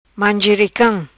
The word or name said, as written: Mangericam